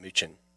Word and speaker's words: München: What the guys do down outside of Munich, or they call it München, that's how the Germans pronounce it, is you put a layer of mustard, and then flour, then egg, then breadcrumbs.